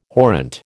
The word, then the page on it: horrent: American English